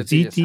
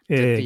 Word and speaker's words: ZTE: ZTE ですかこれは読み方として、はい、 ZTE、はい、 ZTE、激安フォルダブル、 Nubia フリップ 5G と、 Nubia IV で、 Nubia ブランド国内本格展開、激戦区のミッドレンジでシェア獲得できるかという石野純也さんの記事です。